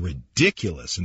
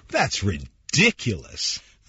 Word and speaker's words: ridiculous: What a ridiculous idea.You look completely ridiculous in that outfit. And you know, when you say ridiculous, you've got to really stress the second syllable. That's ridiculous.